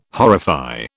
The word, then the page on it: horrify: British English